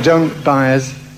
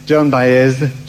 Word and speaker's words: Joan Baez: I zanim może dzieł coś powie o sobie, chciałem uspokoić, nie ma po co się bać, jeśli ktoś nie wie, jak wymawiać jej nazwisko. Właśnie to sprawdzałem, ponieważ jest mnóstwo wersji i wszystkie są poprawne. Zatem Joan Baez, Joan Baez, Joan Baez. Wszystko jest dobrze, okej.